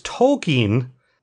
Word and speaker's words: Tolkien: At one point, they reveal because he's the token black kid, but they reveal that his name's not Token. His name is Tolkien because his parents love J.R.R. Tolkien. So everybody has been pronouncing it wrong, and then he finds out that his friends are all calling him Token, and he's like, "Wait, you're calling me what?"